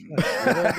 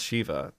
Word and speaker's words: Sheva: I believe it's Sheva, Gary. – Yeah, Gary. – It's Sheva. First you didn't know about Zanshin tactics, and then you don't even know how to pronounce Sheva.